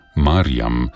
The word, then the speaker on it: Marjam: Ime Mirjam so izgovarjali kot Marjam, kar je bilo lahko spravljati v zvezi z deblom, ki pomeni gospa.